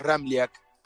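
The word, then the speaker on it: Ramljak: complicado porque tiene tres consonantes juntas y la J se pronuncia como una I. Bien pronunciado el apellido es Ramliak.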